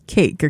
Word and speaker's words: Queic: Now, the spelling is a little bit different, Q-U-E-I-C, but they pronounce it as Cake, okay?